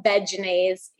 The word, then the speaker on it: Vegenaise: They are the makers of Vegenaise. Yes, that's how you pronounce it.